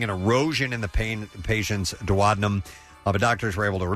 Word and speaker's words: duodenum: A duodenum.